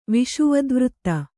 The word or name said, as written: ವಿಶ್ವದೇವತೆ